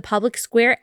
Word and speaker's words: Public SQ: All you have to do is download the Public Square app. So wherever you download your apps, just go to the app store, type in Public SQ. It's spelled Public SQ, but it's pronounced Public Square.